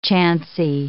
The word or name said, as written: chancy